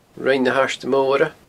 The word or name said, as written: Rinn na h-Airde Moire